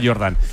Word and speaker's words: Jordan: Que se escribe Jordan, pero como Michael Jordan, ¿no? Úrsula Jordan. – Sí.